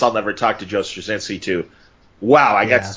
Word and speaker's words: Straczynski: Straczynski, however you say Straczynski.